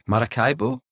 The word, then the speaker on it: Maracaibo: So I'll start by asking you to tell me about Maracaibo.